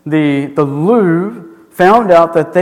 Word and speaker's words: Louvre: The Louvre, just the Louvre.